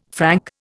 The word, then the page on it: Frank: Indian